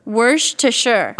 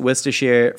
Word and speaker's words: Worcestershire: I believe this is pronounced Worcestershire, and I have pronounced it Worcestershire for the longest time. And recently I've gotten a couple comments from people that go, "Oi, mate, I'm British," and that's correct.